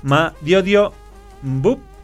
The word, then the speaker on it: Madiodio Bup: Estamos con Daniel Carmona, con Daniel Vera y con Madiodio Bup. Sí. ¿Lo dije bien? – Sí. – Bueno, lo dije bien.